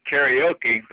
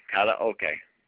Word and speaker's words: karaoke: But you know they say karaoke, if you spell it the way they're pronouncing it, it would be K-E-R-I-O-K-I. But actually, it's kara. K-A-R is kara. O-K-E is okay. Kara okay.